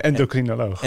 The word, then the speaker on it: endocrinoloog: Nee, endocrinoloog.